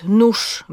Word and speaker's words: nóż: Mówimy na przykład nóż, mimo że piszemy ż, wóz, mimo że piszemy z, nie widzę gwiazd, mimo że tam jest z i dy.